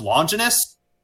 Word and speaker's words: Longinus: In the older one, it was pronounced Longinus.